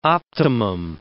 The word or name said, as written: optimum